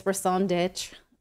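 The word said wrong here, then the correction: raison d'être: like your raison d'etre. – Yeah. Your reason for being.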